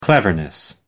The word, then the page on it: cleverness: American English